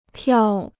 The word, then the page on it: 票: piào